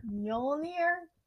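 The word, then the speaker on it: Mjölnir: That's how you pronounce it? – Yeah, you pronounce it Mjolnir. – But he's like the only one who says it, so, and he has like an accent, so it's like Mjolnir.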